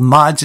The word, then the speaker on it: Madz: Coming up next is a band called Mads. It's spelled M-A-D-Z.